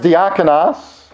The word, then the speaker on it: diakonos: The word is pronounced diakonos.